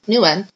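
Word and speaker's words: NeuN: Basically, there's something called, I don't know how you pronounce this. How do you pronounce this? Nu-N? – Nu-N.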